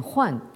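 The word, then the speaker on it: Huan (患): cœur en dessous et qui se prononce en chinois moderne Huan et qui veut dire donc tout ce qui peut être le motif d'une inquiétude, d'un souci ou d'une souffrance.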